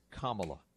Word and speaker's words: Kamala: When we get back, more on the pick of Kamala Harris as Joe Biden's vice president. – Hey, Jor, I looked it up. It is Kamala. That is the preferred pronunciation.